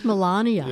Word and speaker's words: Melania: It's Melania.